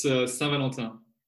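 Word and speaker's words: Saint-Valentin: So now we're going to be talking about Valentine's Day, and Valentine's Day is pronounced different in French, isn't it? – Yeah, it's Saint Valentin.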